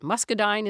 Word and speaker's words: muscadine: By the way, we should mention that muscadine is how you pronounce it.